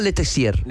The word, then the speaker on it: Letessier: François Letessier. – Letessier. – Letessier. Entiendo que en francés las consonantes finales no se pronuncian, no se nombran. – El ER se pronuncia E.